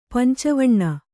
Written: ಪಂಚವಣ್ಣ

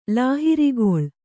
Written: ಲಾಹಿರಿಗೊಳ್

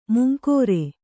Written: ಮಂಗರೆ

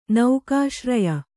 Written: ನೌಕಾಶ್ರಯ

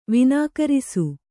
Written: ವಿನಾಕರಿಸು

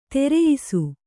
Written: ಧರಿಯಿಸು

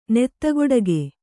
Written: ನೆತ್ತಗೊಡಗೆ

